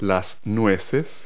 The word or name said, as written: nuts